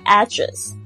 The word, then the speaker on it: address: 就是说你住的这个时间是多长多短，还有你在家，还有你住宿的地址，就是 home address or hotel address。大家掌握的是 address 这个英文 A-D-D-R-E-S-S address。也有呢，就是也有另外一个发音是 address，两个都可以， address 或者是 address。